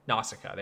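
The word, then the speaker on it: Nausicaä: Nausicaa? – Nausicaa, there you go.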